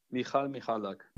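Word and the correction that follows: Michał: Alexander Supertramp sagt, der Vorname wird Mihau ausgesprochen. Mihau Michalak.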